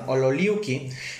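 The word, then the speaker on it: ololiuqui: Y además de que encontraron como esto del tabaco, también hallaban una planta que proviene de la semilla que se llama ololiki. si lo pronuncie bien? – Ololiki. – perdón, ololiuki, que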